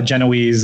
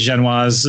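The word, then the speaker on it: génoise: She's like, we call it Genoese. And Ermine was like, it's Genoise or the French pronunciation.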